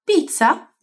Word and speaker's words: pizza: Pois bem, essa palavra tem dois Zs e é falada como se no lugar desse Z a gente tivesse um TS. Tss. Esse tss, que seria um TS, é uma das pronúncias que a letra Z tem em italiano. Se você fala pizza em português, você também fala pizza em italiano. A pronúncia é igual.